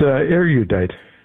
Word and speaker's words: erudite: Is it how do you pronounce it? – It's erudite. – That's what I thought. It's three syllables, right? – You can do three, but more like two. – Two? – Yeah, two. Erudite. Yeah, three. That's good. – Erudite. Erudite. – Yeah, perfect. Yes.